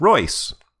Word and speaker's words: Reuss: Yes, I just learned, listening to an old episode of Ludology, that it's pronounced Royce. Eric Reuss. Like choice or voice.